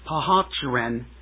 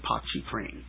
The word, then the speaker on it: Pontchartrain: But the longest bridge in the United States is Lake Pontchartrain Causeway in Louisiana. Now, I think I pronounced that wrong. Lake Pontchartrain in Louisiana.